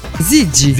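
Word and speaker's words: Ziggy: Abbiamo appena ascoltato Gigi Marley. – No, attenzione, no, Gigi. – Zigi. – Zigi. – Perché io ho la pronuncia della Z, che è un po' particolare. Sì, Zigi.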